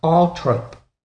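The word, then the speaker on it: Althorp: Weirdly, Cowell says, the name is pronounced Althorpe. Diana's brother, Charles, the current Earl of Spencer, specifies this pronunciation.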